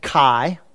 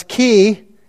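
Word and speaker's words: chi (χ): If you were in a fraternity, you wrongly pronounced it chi. But it's chi, A, B, B, A, right?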